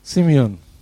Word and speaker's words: Simeon: Do I pronounce it right? Simeon. I hear so many people pronouncing it. Simeon. Very good.